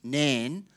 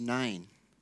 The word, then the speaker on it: Nain: When I first read it, I thought it was Nan, but it's actually pronounced Nain.